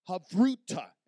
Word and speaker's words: Havruta: In Jewish tradition, what they would do when the rabbis would begin to teach, they would practice what they call Havruta. Everybody say Havruta. Now, if you're taking notes, that's spelled H-A-V-R-U-T-A, Havruta.